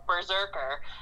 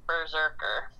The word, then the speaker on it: BRZRKR: Him and Matt Kint are going to work together on this, called Berserker, and it's spelled B-R-Z-R-K-R. Berserker.